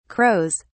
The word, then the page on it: crows: British English